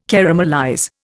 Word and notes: caramelize: Indian

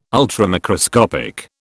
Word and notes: Ultramicroscopic: British English

